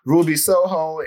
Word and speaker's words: Ruby Soho: Ruby Soho and, I say her name because I don't want to fuck that up again. – You got it right.